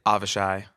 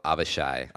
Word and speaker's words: Avishai: Avishai. – Avishai. – It's spelled wrong in English. – It's spelled wrong in English. – Yeah. – But it's pronounced Michael Avishai.